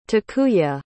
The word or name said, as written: takuya